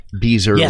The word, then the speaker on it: Beezer: So you're interviewing Scott Beezer, rhymes with geezer.